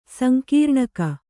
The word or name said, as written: ಸಂಕೀರ್ಣಕ